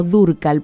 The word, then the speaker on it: Dúrcal: Rocío Dúrcal, entonces, o Dúrcal, porque lleva un acento en la U.